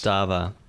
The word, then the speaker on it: Bodhisattva: Bodhisattva is my Bodhisattva. – Guess. – Bodhisattva.